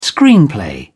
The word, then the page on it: screenplay: British English